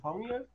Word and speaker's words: pneumonia: I know when I was watching that video, and I said, huh, why is the P in pneumonia? If we don't pronounce the P, is it pneumonia? Like, well, how do you pronounce it with the P? – Well, the hour one kind of throws me out, too. – Right. Yeah, I'm trying to figure how to pronounce it with a P.